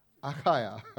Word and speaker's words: Achaia: While Gallio was proconsul of Achaia, I think that's the way it's pronounced. I tried to learn that. Achaia.